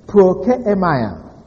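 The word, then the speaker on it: Prokeimaia (πρόκειμαι): Now, the word serve here is really a translation of the Greek word prokeimaia. Prokeimaia is spelled P-R-O-K-E-I-M-A-I.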